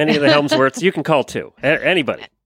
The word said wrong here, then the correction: Hemsworth: Hemsworth. – Hemsworth. Why do I always say Helmsworth? – Because you mispronounced the name Steve.